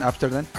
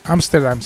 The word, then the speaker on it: Amsterdam: Pues Amsterdam, no sé si lo pronuncio bien, Amsterdam. – Amsterdam, sí, Amsterdam.